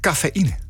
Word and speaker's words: cafeïne: Staat er een trema in het woord cafeïne? Staat er een trema? – Ja. – Het woord cafeïne en het antwoord is inderdaad ja. Het trema laat zien dat er bij de I een nieuwe lettergreep begint.